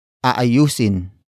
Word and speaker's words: aayusin: Aayusin ko ang kotse bukas.